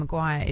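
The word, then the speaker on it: McGuire: You know, my surname, Maguire, is spelled big M, little C, big G, U-I-R-E, right?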